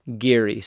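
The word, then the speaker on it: Garioch: Now, if you're an English speaker from the USA or another non-Scottish location, Giri looks like Gerioch. And I looked it up on Wikipedia and it said it's pronounced Giri.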